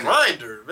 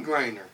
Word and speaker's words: Griner: Griner, man. Like a heated hoagie.